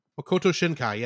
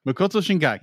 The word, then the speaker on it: Makoto Shinkai: I'm going to butcher his name to death, but he's an amazing director. Mikoto Shinkai? – Makoto Shinkai, yeah. – Makoto Shinkai.